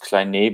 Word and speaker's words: Klein: Most people say Konstantin Klein. In German, it's actually pronounced Kleine because the E is not silent, but I think we are on a first name basis anyway, so we can let that slip.